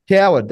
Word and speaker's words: Cowherd: We have some big, I've been tuning into a lot of stuff with, you know, NFL, Fox and The Herd, and Colin, how do you say Colin Cowherd? Is that how you say his last name? – Coward, Cowherd? Colin Cowherd. – Coward, there you go, Coward.